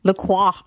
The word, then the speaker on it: LaCroix: LaCroix. I never even took French. LaCroix. – That's the correct pronunciation, but I don't say it that way because I don't want to sound bouge.